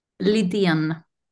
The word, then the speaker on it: Lydien: Lydian. – Lydien, because there's an Yeah. – accent on the E. So it's Marie Lydien.